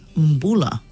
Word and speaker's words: Mbula: So if you see the letter B, it's pronounced like a MB. So when you, something like the word number, yeah, so MB. So that means when we say hello in Fiji, is Mbula.